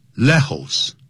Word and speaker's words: Lejos: 只有少数像呢 J 你要念成不能念成 J, 也念成 H 的音，所以呢 Lejos L-E-J-O-S 这是西班牙文，不能念成 Lejos。